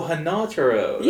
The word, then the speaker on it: Hanataro: To be quite honest, the way you're envisioning Hamataro helping Bleach is, in fact, the same way that Hamataro enhances Bleach. Like, it's just, like... – Oh, Hanataro. That's how they pronounce it in the show.